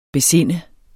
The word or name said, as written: besinde